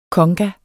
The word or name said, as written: conga